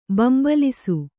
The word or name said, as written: ಬೆಂಬಾಲಿಸು